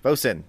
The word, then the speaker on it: boatswain: That makes that would make the boatswain bosun. That's it. I see the pronunciation now.